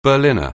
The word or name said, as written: Berliner